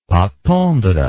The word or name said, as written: pâte tendre